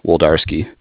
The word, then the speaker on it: Wlodarski: It's WLODARSKI, Woldarski.